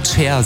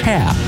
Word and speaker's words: Cher: Ich glaube, die meisten von Ihnen würden auch Cher sagen, ne? – Ja, würde ich auch sagen. – Warum hat Cher gesagt? Aber ich musste mich belehren lassen, es heißt Cher.